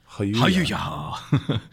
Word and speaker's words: Jayuya: It's spelled J-A-Y-U-Y-A. – Hajuja.